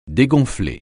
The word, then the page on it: dégonfler: French